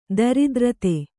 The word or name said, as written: ದರಿದ್ರತೆ